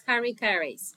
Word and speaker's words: Harry Caray's: Roberto, Ricardo, Richie de las Nieves, como se les cante decirle, consiguió trabajo como camarero en Harry, Harry Carais. ¿Cómo se dice eso? Ese es Harry Caray's.